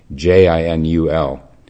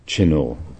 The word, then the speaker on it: Chinul: Uh, I've been pronouncing it Chinu, uh, and then uh, I found another book uh, about him um, that it spells it uh, with a J instead of a CH. J I N U L. Jinul. So I don't know how it's pronounced, but uh, let's sort of soften that a little bit now. Let's make it uh, Chinul.